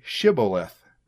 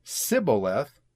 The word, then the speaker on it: Shibboleth: If he said no, then they would say to him, "Say Shibboleth." If he said Sibboleth, for the Ephraimites could not pronounce it right, then they would grab him and kill him at the fords of the Jordan River.